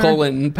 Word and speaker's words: Colin: He's weirdly Colin Powell. And I don't want to pronounce it that way because it sounds weird. It sounds like indigestive.